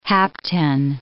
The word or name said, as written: hapten